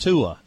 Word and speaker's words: Tua: Tua is about as I know the first name is pronounced Tua.